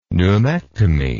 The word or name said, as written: pneumectomy